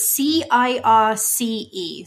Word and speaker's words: Circe: Uh, so it's C I R C E, Circe.